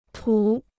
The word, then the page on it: 土: tǔ